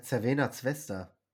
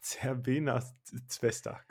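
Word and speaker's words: Crvena zvezda: Also wie hast du es gerade ausgesprochen? – Ja, Cervena Zvezda. – Cervena Zvezda, genau.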